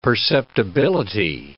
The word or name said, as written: perceptibility